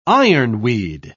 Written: ironweed

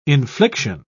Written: infliction

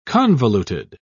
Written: convoluted

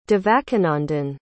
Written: Devakinandan

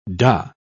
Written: dah

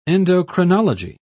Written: endocrinology

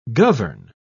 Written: govern

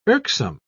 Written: irksome

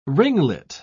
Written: ringlet

